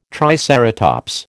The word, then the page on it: triceratops: American English